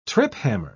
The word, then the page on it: triphammer: British English